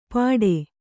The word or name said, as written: ಪಾಠೆ